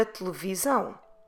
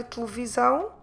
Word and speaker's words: televisão: So I'm dropping completely those first two E's, or in Portuguese, es, of the word, because they're not stressed. I'm stressing in this one, I'm stressing the last syllable, where the accent is, where we have the diptong ão. So, televisão, televisão.